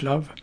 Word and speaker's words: Lowe: Han heter Mandel Love. Det stavas L-O-W-E. Jag tror det ska uttalas Love.